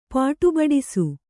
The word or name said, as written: ಪಾಟುಬಡಿಸು